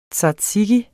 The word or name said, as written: tzatziki